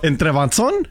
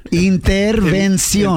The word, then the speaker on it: intervención: ¿Entrebanzón? – Intervención. – Entrebanzón. – No, es que te está mirando a ti, Shaboy, dijo panzón. – Prima Vani, can you say intervención? – Interversión.